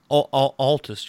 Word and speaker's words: Altus: I think it's pronounced Altus. I think it's Atlas. I think it's Altus. A-L-T-U-S. – Altus. Yeah, you're right.